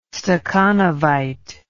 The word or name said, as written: stakhanovite